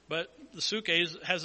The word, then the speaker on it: psuche: In the New Testament, the word translated soul comes from the Greek word psuche, P-S-U-C-H-E. Seen it spelled different ways, but basically the spelling in English only helps you so you can pronounce it. So we see nephesh in the Hebrew. We see psuche, P-S-U-C-H-E, in the Greek for this word soul.